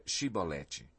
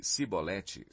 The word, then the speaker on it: Shibolete: Se respondesse que não, diziam: Então diga Shibolete. Se ele dissesse Sibolete sem conseguir pronunciar corretamente a palavra, prendiam-no e matavam-no no lugar de passagem do Jordão.